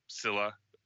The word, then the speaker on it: Scylla: there's Scylla, I think that's how you pronounce it. – Yep.